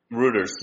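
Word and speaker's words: Reuters: Too much rotors. Rooters. How do you say it? G, I always screwed up. – Rooters, yeah.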